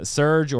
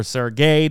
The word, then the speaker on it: Serge: We've got Serge or Sergey, depending on how you want to pronounce it in your area, Kid and Harley in another Eden, so you can pick those up.